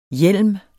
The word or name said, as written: hjelms